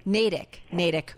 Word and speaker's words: Natick: Did I say it right? Because I always mispronounce. No, Natick.